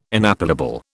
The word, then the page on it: inappellable: British English